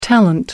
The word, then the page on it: talent: American English